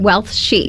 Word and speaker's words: Wealthy: Wealthy. – Yeah, so what's that all about? – Yeah, well, of course, there's the word Wealthy, and emphasis on the he there.